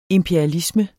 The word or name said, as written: IMPERIALISMEN